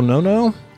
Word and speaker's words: Nonno: Today, my guest on the show is Lamberto Nono. Lamberto, did I say that right? – Yeah, indeed. Nono. Yeah, it's a double N.